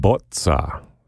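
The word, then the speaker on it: Bozza: Music by Eugène Botza, 20th century French composer with an Italian father, if you notice my pronunciation of his last name.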